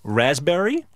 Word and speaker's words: raspberry: K-N-E-E-L, Neil. – Raspberry, answer. – Silent letters. – Yes, silent letters. – Right. – Raspberry is the silent S. – P. – Well, P. Sorry.